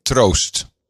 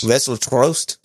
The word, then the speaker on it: Troost: What's the last name? How do you spell it? – T-R-O-O-S-T. – Trost. – Trost. – Yes. – Vessel Trost. Is that perfect? Did I nail it? – Yeah, you nailed it.